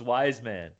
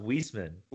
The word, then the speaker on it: Wiseman: No, but it's not pronounced Wiseman. – Wiseman. – It's pronounced Wiesman.